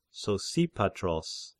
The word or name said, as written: Sosipatros